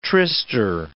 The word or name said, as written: tryster